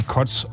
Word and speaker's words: Koc: Denn wenn ich jetzt mal den Namen nur K-O-C oder K-O-Z schreibe, das wird im Polnischen wie Kotz ausgesprochen.